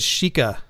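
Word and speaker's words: Xica: It's a X I C A, and um, I think it's Chica de Silva um, is how you would uh, pronounce that in Portuguese.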